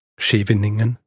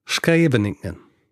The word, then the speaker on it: Scheveningen: Heißt nicht Scheveningen, wie ich gesagt habe, sondern Scheveningen. Scheveningen.